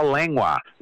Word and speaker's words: Langlois: We have Al Langlois, and that's how you say it, L-A-N-G-L-O-I-S, but the L is silent, for the second L, Al Langlois.